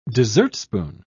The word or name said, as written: dessertspoon